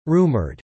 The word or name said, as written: rumoured